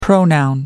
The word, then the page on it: pronoun: American English